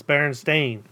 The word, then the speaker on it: Berenstain: It's Berenstain. – I don't. – It's spelled with an A, not an E.